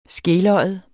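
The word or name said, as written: skeløjet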